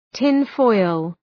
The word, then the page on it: tinfoil: ‘tınfɔıl